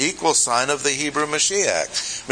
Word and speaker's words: Mashiach: Throughout the Old Testament, you have a connection between Yahweh, the only true God, and His Messiah, pronounced Mashiach in Hebrew, and the association was intimately close.